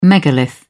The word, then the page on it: megalith: British English